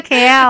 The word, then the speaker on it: kale: 它就是 Kale 羽衣甘蓝。 – 是的，这个羽衣甘蓝，一开始我看到它的名字的时候都不知道怎么念呢，K A L E 怎么样念呢？哦，你就把它想成是吉隆坡就可以了。 Kale，Kale，Kale，你有没有吃那个 Kale？